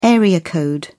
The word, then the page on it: area code: British English